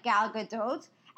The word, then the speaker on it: Gal Gadot: I think, because her name is Hebrew, and in Hebrew you usually pronounce T's, whereas in French you don't. So I think they just sort of America Frenchianized. That's a terrible term. Her name. And I am convinced it's actually Gal Gadot.